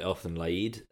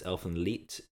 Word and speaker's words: Elfen Lied: Well, what I always thought was called Elfen Lied. Apparently it's Elfen Lied, which is because it's a German pronunciation.